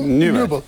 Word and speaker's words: Nübel: Beşiktaş'ın yıllarca konuşabileceği, 2-3 sezon burada kaleyi koruyabileceği, bu konuştuğumuz, nasıl telaffuz ediyorsun, Nübel mi diyorsun ona? – Nübel.